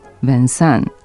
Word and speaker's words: Vincent: Vincent, piše se Vincent, ima isti početni slog kao Vinci,